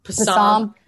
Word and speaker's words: Psalm: Like, because, you know, you don't really know the silent P, the whole thing. – Yes. Growing up, it's got to be hard for them. – Psalm.